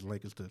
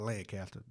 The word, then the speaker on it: Lancaster: I got to say that right here because it's Lancaster, South Carolina, not Lancaster.